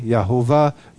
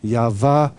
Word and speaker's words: Yahweh: And then over the millennia, our best guess is Yahweh. It's not Yahweh because W is not pronounced in Hebrew. Yahweh, Yahovah, Yahvah.